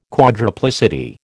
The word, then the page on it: quadruplicity: American English